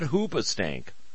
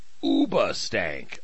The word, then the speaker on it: Hoobastank: That's how you say it, not Hoobastank. Oobastank.